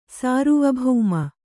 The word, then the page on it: ಸಾರ್ವಭೌಮ: sārvabhauma